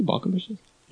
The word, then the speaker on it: Balkenbush: You guys know the Balkenbushes? – Yes. Yeah, yeah. Balkenbush. Rhymes with Bush.